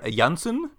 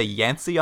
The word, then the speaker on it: Janssen: This is Dr. Janssen. I kept hearing them say Yancey on the show, but I think it's actually Janssen was what the actual written word was.